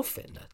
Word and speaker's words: Fichtner: And the accountant, played by the aforementioned William Fichtner, or Fitner, depending on your pronunciation.